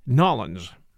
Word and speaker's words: New Orleans: I'm still trying to get the pronunciation right, but as I understand it, the locals call it Nollins.